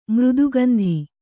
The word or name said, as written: ಮೃದುಗಂಧಿ